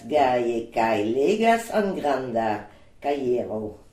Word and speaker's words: kajero: En granda caiero. Très bien, un grand cahier. Alors un cahier, ça s'écrit caiero, donc ça s'écrit K-A-J-E-R-O, cahier.